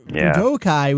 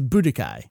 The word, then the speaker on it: Budokai: And it always got slurred into Budokai. – Yes, it's one of those weird things that they put the emphasis on the wrong syllable. Now, it must be stressed that Japanese does not have a stress accent. However, it has long and short vowels, yes.